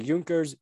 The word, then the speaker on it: Junkers: I have to admit, I forget that sometimes in German that J's are supposed to be pronounced as Y's. But yes, it was the Junkers JI.